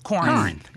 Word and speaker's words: Corinth: We scrunch it all together. – Corinth. – And then put like some high mids, some like nasalish. – Corinth. – Yeah, Corinth. Just say it like that.